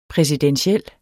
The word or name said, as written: præsidentiel